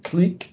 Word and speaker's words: clique: And one word I wrote down was, well, I'll spell it out because I think it's pronounced differently in North America than it is in the UK, but I would pronounce it Clique, C-L-I-Q-U-E.